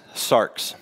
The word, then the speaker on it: sarx: The Greek word for flesh is sarx, so we spell it S-A-R-X, but sarx.